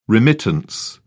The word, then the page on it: remittance: British English